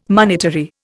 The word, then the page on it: monetary: Indian